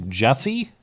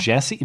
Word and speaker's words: Jussie: his name is Jussie, not Jesse? J-U? – Yeah.